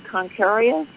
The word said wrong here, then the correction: Aquarius: Did I say it wrong? – Aquarius.